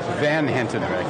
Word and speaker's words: Van Hentenryck: My friend, Kevin Hentenryck. – Van Hentenryck. – Van Hentenryck. See, I was concentrating on the buh-buh-buh at the end.